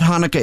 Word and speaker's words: Hanukkah: Sorry, isn't it spelled with an H? – It's spelled C-H. There's a couple different ways to spell it. But, Chanukah. – But I mean, if you've been around 80 years, you should know that that's Hanukkah, or look it up instead mm-hmm.